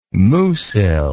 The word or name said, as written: musil